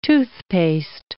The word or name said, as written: toothpaste